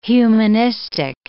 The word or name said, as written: humanistic